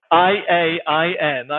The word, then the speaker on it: Iain: So, um, I A I N.